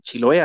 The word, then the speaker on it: Chiloé: Chiloé, así con acento en la E.